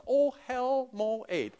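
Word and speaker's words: oh, hell, moed (אהל מועד): But then there's this, oh, hell, moed. I wasn't swearing here. But that is the way it's pronounced. Oh, hell.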